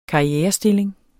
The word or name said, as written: karrierestilling